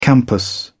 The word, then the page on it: campus: British English